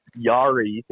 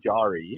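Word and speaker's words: Jarry: Yari to the example. thank you, Maddie Cool, for that. It is Tristan Jari. – Yeah, always has been Jari too.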